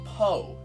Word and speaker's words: pot: And does pot mean a pot? And she said, no, yeah, but it's not pronounced pot, it's pronounced po, because pot means a stupid idiot.